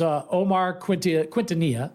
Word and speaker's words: Quintanilla: His name was Omar Quintanilla. That's how you pronounce it.